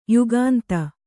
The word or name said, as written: ಯುಗಾಂತ